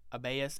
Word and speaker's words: Abaius: I don't want to say it wrong. Abaius, A-B-A-I-U-S.